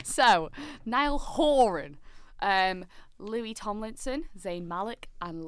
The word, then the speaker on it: Horan: Because James Corden asked Niall and he said it's Horan.